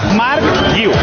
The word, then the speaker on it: Marc Guiu: Marc Guiu. – Mare de Déu. – ¿Cómo se dice que me lo tengo que aprender? Me lo tengo que aprender. – Apunta. – Marc Guiu. – Guiu. Fácil.